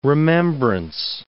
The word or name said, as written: remembrance